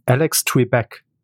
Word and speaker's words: Alex Trebek: Ähm, Alex Trebek.